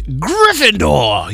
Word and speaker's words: Gryffindor: It's not Gryffindor. Did I say it right? – Yeah, Gryffindor.